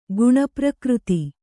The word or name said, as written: ಗುಣಪ್ರಕೃತಿ